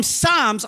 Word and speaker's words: Psalms: And it's from Psalms. I feel like I should say Psalms, but I can't because it's a silent P.